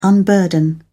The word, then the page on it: unburden: British English